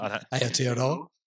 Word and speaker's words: Aotearoa: So you would have the Super Rugby AU playoffs and the Super Rugby NZ or Aotearoa. You know how to pronounce it. – Aotearoa.